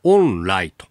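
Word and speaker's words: 鳳梨: 湾の言葉で発音するとオンライ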